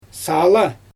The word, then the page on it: saala: Xyzyl